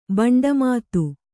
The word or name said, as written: ಬಂಡಮಾತು